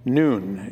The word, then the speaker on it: Nun: Nun is how it's pronounced.